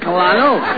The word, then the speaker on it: Colonel: In military parlance, C-O-L is pronounced Cur. – Oh, as in Colonel.